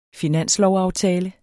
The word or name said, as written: finanslovsaftale